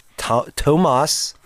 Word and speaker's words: Tomas: So the H is silent. – There is no H. – Oh, okay. So it's not silent. – It's T-O-M-A-S.